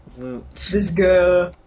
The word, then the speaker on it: Disgaea: Disgaea. – Yes. – Disgaea.